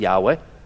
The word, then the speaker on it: Yahweh: Y-H-W-H. We think it's pronounced Yahweh. There's no vowels, it's just YHWH in the Hebrew text.